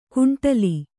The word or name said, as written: ಕಂಠಾಲ